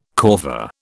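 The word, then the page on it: corvée: British English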